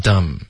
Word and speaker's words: dumb: 所以，比如我们有几个字呢，有一个 M，然后后面带一个 b 的时候呢，然后这样这个字的结尾呢，这个 b 的音就不发音了。比如 num。跟 dumb，因为这个嗯不的这个发音就有点别扭，然后我们就不喜欢发，然后现在这些字呢就不发音了。